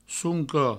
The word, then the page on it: soncop: sounco